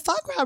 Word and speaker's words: foie gras: Well, the foie gras recipe changes sometimes. – Say it again. I never say it right. – Foie gras. – Foie gras. I say foie de gras.